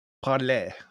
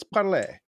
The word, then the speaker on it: parler: It is actually, and it's pronounced parler. So it's parler.